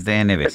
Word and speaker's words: DNB: DNB, perdón, porque lo dije mal, es DNB, sí.